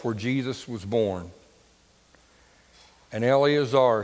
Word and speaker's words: Eleazar: Eleazar. Not Eliezer. Because I always pronounced it that way until I got to Israel.